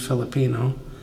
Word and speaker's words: Filipino: She said, "I didn't say that right, did I, Mom?" I said, "No, honey, you didn't." I said, "Your friend or your coworker, I'm assuming, is from the Philippines, and she's a Filipino."